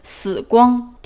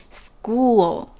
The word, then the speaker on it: school: 我记得以前有人说就是一些英语初学者把这个 school 标上了汉语，就是死光，听着挺瘆人的，但是实际上不是那个死光的发音，其实是 school，这个 oo 它是一个长音 u，然后是一个 o， school 这个词是一个名词，表示学校。